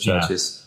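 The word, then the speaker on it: Chvrches: It would be, I don't know how to pronounce this, Churches? Was it just Churches? But it's with a V instead of the U. – Yeah, just Churches. Yeah. – Is it just pronounced Churches? – It's just a stylistic thing.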